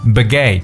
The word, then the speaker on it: baguette: 我们说这种法式长棍叫 baguette, baguette 这个词呢是按照法语的发音来处理的，所以有点不一样。这个词是 B-A-G-U-E-T-T-E、 okay? baguette 就是法式长棍的意思。